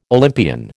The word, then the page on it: Olympian: American English